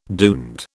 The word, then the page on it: duende: British English